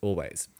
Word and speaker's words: Alvvays: A-L-V-V-A-Y-S, but it's pronounced Always.